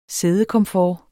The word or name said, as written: siddekomfort